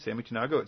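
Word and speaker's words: Samy Tanagho: I heard him speak at a local church here in town, and his name is, as I mentioned, Sammy Tanago. It's spelled S-A-M-Y, so just one M, and then T-A-N-A-G-H-O.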